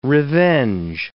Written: revenge

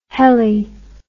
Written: helle

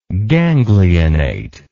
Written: ganglionate

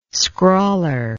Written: scrawler

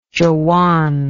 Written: jawan

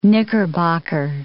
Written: knickerbocker

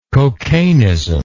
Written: cocainism

